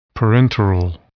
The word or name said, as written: parenteral